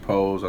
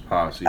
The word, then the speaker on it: Pose: That's how you pronounce it, Pose. I thought it was Posse. – Oh no, Pose.